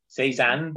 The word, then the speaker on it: Cézanne: He would fire it at people he found boring or anyone who insulted the post-impressionist painter Paul Cézanne or Cézanne. I don't know how you say his name. – Cézanne, boy.